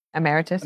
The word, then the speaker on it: emeritus: emeritus. – Emeritus. Yes. Am I saying that correctly? – Correct.